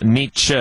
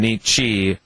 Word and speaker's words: Nietzsche: Uh, both Nietzsche and Nietzsche are acceptable according to Wikipedia on Friedrich, your favorite German philosopher.